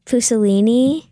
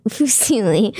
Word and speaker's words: Fusilli: So the first one was Fusilini. What? Fusilli.